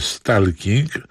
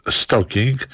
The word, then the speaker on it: stalking: Otrzymałem pytanie językowe od słuchacza pana Stefana, który zastanawia się, czy w wyrazie stalking L nie jest przypadkiem nieme, tak jak w słowie walking angielskim. Problem jest rzeczywiście pewien, bo stalking po angielsku przeflancowane do Polski jest przez większość mówców no, spolszczone w wymowie i mówi się stalking, a z kolei walking nie mówi się walking, więc sprawa jest jak to zwykle uznaniowa, a specjaliści może się wypowiedzą.